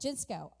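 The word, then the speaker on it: ginosko: According to Vine's Bible Dictionary, Ginsko. That's how you say it, Ginsko.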